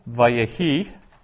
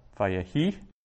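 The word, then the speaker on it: Vayehi (ויהי): So verse 1, Vayehi. So the accent is under the is over the last syllable. It's the diamond over the hay, called a rabia, Vayehi. So I accent that last syllable.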